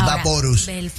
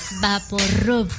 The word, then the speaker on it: VapoRub: Vix Vaporus. – Vix Vaporus. – Vaporub. Vaporub. Vic. – Tú lo estás diciendo en mexicano. Borico es Vix Vaporus.